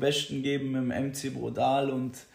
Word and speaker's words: Brudaddal: Den schreibt man B-R-U-D-A-D-D-A-L. Brodal.